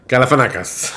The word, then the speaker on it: Galifianakis: Galifianakis. – Learn how to say it. – Oh, I said it right? – You said it right.